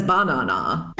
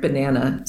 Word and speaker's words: banana: But in the most common way that we hear the word banana said, it's got a schwa in the first syllable and a schwa in the second syllable. Ba-na-na. That a sound is the schwa.